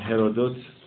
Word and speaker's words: هرودوت: مردیست زاده شهر هالیکارناسوس در کشور ترکیه امروزی به اسم هرودوتوس، یا رایشتر بنابرای تلفظ فرانسه این نام که ما بیشتر باهاش احساس الفت میکنیم، هرودوت. او